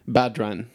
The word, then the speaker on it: Badran: Badran.